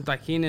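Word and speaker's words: Tajín: Put some Tajin in it for everyone. – Thank you for pronouncing it correctly.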